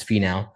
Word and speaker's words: Finau: So you got Finau. I say his name wrong. It's Finau.